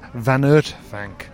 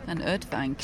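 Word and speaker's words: Van Uytvanck: Incidentally, pronunciation corner, apparently we've been getting it wrong with the old pronunciation of Van Ooytvanck. Apparently it's Van Ooytvanck. – Van Ooytvanck. – So I've been corrected there.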